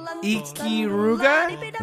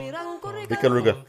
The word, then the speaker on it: Ikaruga: Ikiruga? – Nope. – Nope. How do you pronounce it? – Ikaruga.